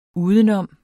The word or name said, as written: udenom